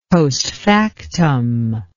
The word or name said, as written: postfactum